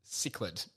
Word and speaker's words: cichlid: It's pronounced cichlid. – Not kitten.